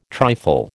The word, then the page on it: trifle: American English